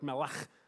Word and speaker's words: melech: And the word king in the Old Testament is typically melech, probably more like melech if you're pronouncing it correctly in the Hebrew.